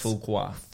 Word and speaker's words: Fuqua: I want well, his last name is Antoine pronounced Fuqua.